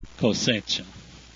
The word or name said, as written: cosecha